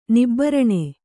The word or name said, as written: ನಿಬ್ಬರಣೆ